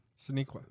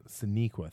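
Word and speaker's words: Sonequa: Sinequa. – Sinequa, thank you.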